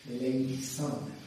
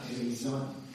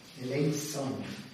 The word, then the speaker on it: Eleison: It's like two S's, okay? Eleison, not Eleison. Okay? Rosette. Eleison is the correct Latin pronunciation.